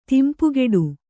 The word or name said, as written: ತಿಂಪುಗೆಡು